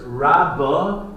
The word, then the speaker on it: Rabba: The first, Rabba, is with a base. Rabba versus Rava. Rava with a vase,